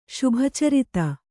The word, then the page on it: ಶುಭಚರಿತೆ: kannada